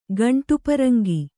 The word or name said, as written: ಗಂಟುಪರಂಗಿ